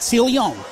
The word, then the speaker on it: Celian: And I did talk to him, and he absolutely wanted it to be known that you had to pronounce it Celian in the French way.